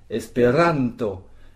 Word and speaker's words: Esperanto: Par exemple, on dit Esperanto. Esperanto, est-ce que j'ai bien roulé le R? Esperanto. Et on met donc l'accent tonique sur le ran, sur l'avant-dernière syllabe.